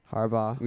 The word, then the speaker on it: Harbaugh: So Smith said on Wednesday that coach Jim Harbaugh and general manager Trent Baalke. – Harbaugh.